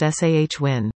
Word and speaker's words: Samhain: Halloween got its start as an ancient Celtic autumn festival called Samhain, pronounced S-A-H-W-I-N.